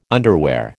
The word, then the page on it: underwear: American English